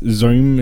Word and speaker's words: Xohm: And Sprint's Zoom, it's spelled X-O-H-M, and I think that's how it's pronounced, but I'm not really sure.